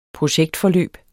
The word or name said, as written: projektforløb